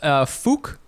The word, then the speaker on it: Phuc: It's Phuc. Like, so it's a, like a, it's more like a, it's a long U. You know, so Phuc and then Bui is the name.